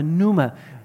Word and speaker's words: Pneuma: Der P ist äh, spricht man nicht aus, aber Numa.